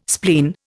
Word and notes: spleen: Indian

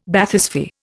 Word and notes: Bathysphere: Indian

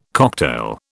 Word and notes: cocktail: British English

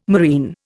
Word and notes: moreen: Indian

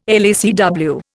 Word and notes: LACW: Indian

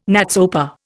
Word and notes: NATSOPA: Indian

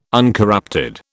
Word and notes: uncorrupted: British English

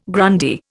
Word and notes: Grundy: Indian

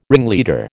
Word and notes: ringleader: American English

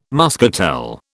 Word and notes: muscatel: British English

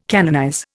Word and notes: canonize: Indian

